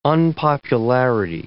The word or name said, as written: unpopularity